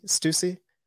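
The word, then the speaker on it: Stüssy: So that's why I'm just kind of giving it a shout out because they did send me do you say it's Stussy? – Stussy. – I wore the shirts in high school and I never knew how to say it.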